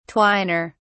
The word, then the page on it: twiner: British English